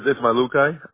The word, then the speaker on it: Ismajlukaj: The last name is Ismailukaj. I-S-M-A-J-L-U-K-A-J.